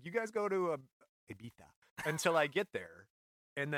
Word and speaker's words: Ibiza: Ibiza, yeah, but I think it's pronounced Ibiza.